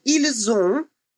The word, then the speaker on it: ils ont: При произношении ils ont делается liaison, то есть связка, так как слово заканчивается на согласную, а следующее начинается с гласной.